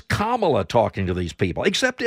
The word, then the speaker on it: Kamala: Kamala Harris, actually it's Kamala, but I mean, nobody can say it right, not even her.